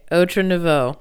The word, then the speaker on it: Outre Nouveau: You're pronouncing it correctly, but you're pronouncing it like a newscaster would say El Salvador. You know, just say Outre Nouveau. – Okay. Outre Nouveau.